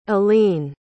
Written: aleen

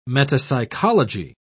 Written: metapsychology

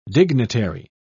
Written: dignitary

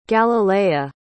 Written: galilea

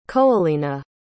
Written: coelina

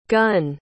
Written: gunn

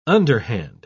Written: underhand

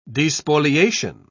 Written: despoliation

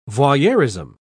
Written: voyeurism

